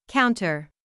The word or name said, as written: counter